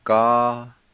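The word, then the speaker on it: galivniha: And before I pronounce this word, I want to mention that I with the L most time is silent. And we pronounce it like this. Ga-la-ni-ha.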